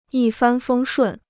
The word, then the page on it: 一帆风顺: yī fān fēng shùn